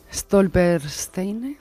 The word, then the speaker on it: Stolpersteine: perdón la pronunciación, ¿eh? Stolpersteine, muy bien pronunciado.